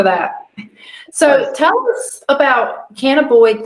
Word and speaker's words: cannabinoid: So cannabis, but cannabinoid.